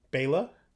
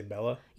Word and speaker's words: Bela: Bela? – Bela. Oh, Lugosi. I've been saying his name wrong. – You say Bella? – Yeah. – Bella has two L's.